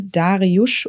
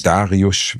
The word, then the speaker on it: Dariusz: Dariusz wird es, glaube ich, ausgesprochen. Mhm. Ich glaube, dass man, also im Deutschen wäre es Darius, aber ich glaube, dass SZ ist, wird es Dariusz ausgesprochen.